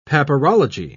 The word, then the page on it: papyrology: British English